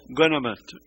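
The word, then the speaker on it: Ouanaminthe: Et on a visité justement une ville frontalière, je ne sais pas si la prononciation est correcte, Unamante, c'est ça? Guanamante, excusez-moi.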